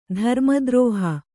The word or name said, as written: ಧರ್ಮದ್ರೋಹ